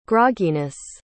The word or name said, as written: grogginess